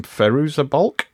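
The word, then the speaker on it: Fairuza Balk: I think my favourite scene, though, in the entire film is when, I don't know how to pronounce the name, Feruza Balk? – Yes, yeah.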